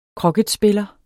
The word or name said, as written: kroket